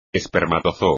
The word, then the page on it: espermatozoo: espagnol